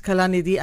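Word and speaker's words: Kalanithi: It's pronounced Kalanidhi. I know that because he's from that part of India where I come from.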